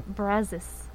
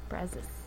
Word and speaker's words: Brazos: It's Brazos. Brazos. – But that's an O. – I know, but it's pronounced Brazos.